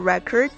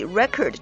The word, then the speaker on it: record: 后边提到的这个 Bergen Record, 它呢是美国新泽西州的一家报纸，我们一般呢把它就可以直接简称为 The Record, 注意 record 这个词。作为动词呢是读作 record, 作为名词呢是读作 record。